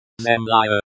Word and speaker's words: Земля: Zemlya, the stress is on the first syllable.